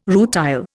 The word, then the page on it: rutile: Indian